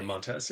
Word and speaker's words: Montez: I believe, because it's the S at the end, not the Z, so I'm like, ooh, I questioned myself. – Yeah, Montez, yeah.